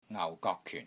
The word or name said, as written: NGAU4 GOK3 KYUN4